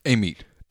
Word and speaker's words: Amite: I think he's from Amite. Is that how you pronounce it? – Amite.